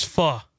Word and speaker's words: pho: Are you saying that right? Is it pho? – It's pho. – Pho.